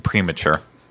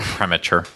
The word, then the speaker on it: premature: Premature. Not premature. That's it. You're putting the emphasis on the wrong syllable.